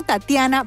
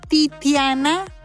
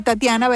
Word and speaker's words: Tatiana: Yo me llamo Tatiana Velázquez y vivo en los Estados Unidos. Cuando una persona muy americana me pregunta mi nombre, ellos lo pronuncian Titiana Velázquez. Titiana Velázquez. Es distinto a Tatiana Velázquez.